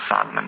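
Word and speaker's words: psalmen: Men till exempel så har vi ord som salm till exempel, eller ljud, där vi då stavar LJ på ljud och salmen med P, men vi uttalar inte P, det är liksom stumt.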